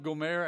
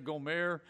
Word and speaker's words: Gomer: Let's go back before we go into Amos a little bit and then embrace Hosea and his wife Gomer, and her name can also be pronounced Gomer. I don't want it to sound like I'm being affectatious, you know. But the name can be both ways.